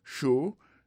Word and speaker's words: sure: Show, S-U-R-E, décrit sur, mais elle prononce show.